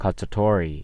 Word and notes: cacciatore: American English

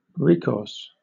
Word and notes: recourse: British English

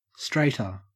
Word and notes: straighter: Australian English

